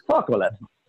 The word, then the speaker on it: Fauxolate: That'd be FAUXOLATE. Focklet. – Foxlet.